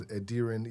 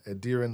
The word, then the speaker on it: Adiran: My name is Adiran Ebru. Adiran, like Adiran headlights.